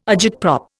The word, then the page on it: agitprop: Indian